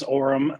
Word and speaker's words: Orem: It is Orem.